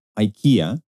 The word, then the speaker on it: IKEA: Álvaro González y Pero como que nos faltó Pero yo pensaba como, es porque ellos en inglés como lo pronuncian como, podrían pronunciarlo como IQEA, eh, e ¿no? IQEA. Pero creo que lo pronuncian como IKEA.